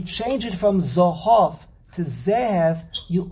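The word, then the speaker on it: Zehev (זהב): Hashem will give us ornaments of Zohov, but the way Le'osilove we're going to pronounce Zohov is not Zohov. It's Zehev. Zehev. Not Zohov.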